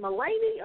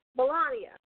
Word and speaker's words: Melania: Is it Melanie or Mel? How do you pronounce it? Melanie? – Melanie. – Whatever. – Melania.